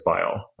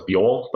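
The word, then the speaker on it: BYOL: One of our researchers implemented Bile, and that's how it's pronounced, like B-Y-L-L is Bile, bring your own latent. Yeah. I always thought it was Buell, but that's a funny way to say it.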